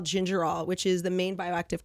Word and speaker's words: gingerol: Is it spelled like ginger ale? – No, it's without the I. – It's like ginger ol.